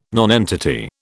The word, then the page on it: nonentity: British English